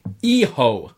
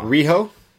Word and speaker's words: Hijo: Just on the YouTube channel, someone's corrected our pronunciation of Fantasma. It's Hijo. – Hijo. – Del Fantasma. – Riho? – Hijo.